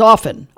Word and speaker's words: often: Do you pronounce the T in often? It's often. I always said it was often. Well, apparently it was often in Middle English until Queen Elizabeth didn't like it and didn't pronounce it.